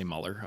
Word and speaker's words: Mueller: It's Mueller in the family when you talk to each other, but if I want it spelt properly, I just say Mueller. Otherwise everyone throws an E after the U, right?